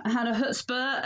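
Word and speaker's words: Hannah Chutzpah: So I am Hannah Hutzper, and you can find me on all the social media under the name Hannah Hutzper.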